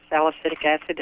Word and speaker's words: salicylic acid: When you mentioned elderberry, elderberry causes my blood vessels in my eyes to burst, as well as aspirin or anything that has salicylic acid.